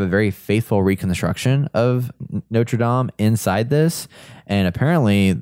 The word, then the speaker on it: Notre Dame: Notre Dame. I don't know how you pronounce that. – Yeah, that's right. That's better that time.